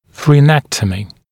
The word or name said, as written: fraenectomy, frenectomy